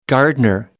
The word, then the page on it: gardener: American English